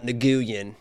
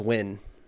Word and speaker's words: Nguyen: Yeah, it's not Nguyen. It's just Win.